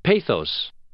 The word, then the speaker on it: pathos: John Rosen of La Jolla, California, and Dale Sellen of Anchorage, Alaska, both wrote to say, "It's not pathos, as I said yesterday, it's..." – "Pathos."